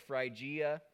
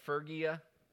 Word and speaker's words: Phrygia: The Y is actually pronounced more like a U. Um, so, so just just go, just go with it. So Phrygia,